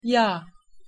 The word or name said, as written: yag